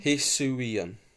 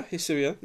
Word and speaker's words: Hisuian: 10 Pokemon that deserve a I can't pronounce it now. – Hisuian. – 10 Pokemon that deserve a Hisuian. – Yeah, Hisuian, yeah.